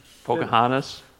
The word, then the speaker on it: Pocahontas: Pocahontas.